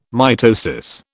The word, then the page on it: mitosis: British English